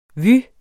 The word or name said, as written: vue